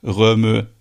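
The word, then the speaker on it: Rømø: Ich habe ja vor einem Jahr gelernt, dass die dänische Nordseeinsel Röme nicht Röme ausgesprochen wird, sondern Röm.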